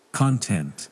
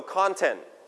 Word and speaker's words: content: So this is now, and you have to remember where the stress is. content or content. Stress on the first or second syllable. – First. – So we pronounce con with high note.